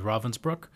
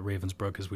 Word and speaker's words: Ravensbrück: It's a camp that doesn't show up on people's collective imaginations as much as it should, but it's called Ravensbrück. That'd be the German pronunciation. You might hear me call it Ravensbrück as we talk about it.